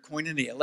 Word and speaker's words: koinonia: And by the way, Carrie, that's the password that you're always trying to figure out, the word koinonia. Let me tell you how to spell it. It's spelled K-O-I-N, coin, O-N, on, I-A. K-O-I-N-O-N-I-A. Coin.